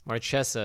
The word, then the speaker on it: Marchesa: Marchesa is what the Wizards pronunciation guide says.